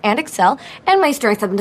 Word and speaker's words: Excel: Wspaniałym przykładem są też nazwy programów z rodziny Microsoftu, czyli, tak jak my po polsku mówimy, Word i Excel. Tymczasem po angielsku przecież mówimy Word albo Word i Excel, czyli akcent na drugą sylabę.